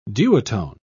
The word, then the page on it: duotone: British English